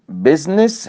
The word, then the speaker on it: business: وخلي بالكم بقى حضرتك في ثلاث كلمات بنخطئ كثير في نطقهم، منهم اللي هي medicine business history. احيانا نقول ميديسن عشان الاي، بيزنس عشان الاي، لا، او هيستوري عشان الاو، لا، هي مقطعان ضيا سيلابك. ميديسن بيزنس هيستوري، امتى اقول الاي؟